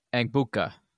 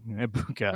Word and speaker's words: Egbuka: Amika Egbuka. – Egbuka.